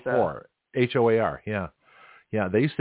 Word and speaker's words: Hoar: Or there's a politician whose last name is H-O-A-R, pronounced whore.